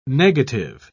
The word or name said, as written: negative